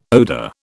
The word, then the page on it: odor: British English